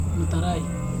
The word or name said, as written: nutarai